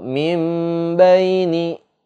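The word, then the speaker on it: mim baini (مِن بَيْنِ): Kalau kita baca dalam huruf iklab itu nggak boleh baca min baini gitu. Min baini itu salah. Nun mati ketemu ba hukumnya eh, iklab maka cara bacanya adalah mim dimasukkan nun sukunnya ke dalam huruf mim. Mim baini, mim baini gitu disertai dengung sebanyak dua harokat gitu.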